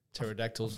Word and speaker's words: Pterodactyls: Pterodactyls.